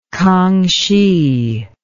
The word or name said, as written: kanghsi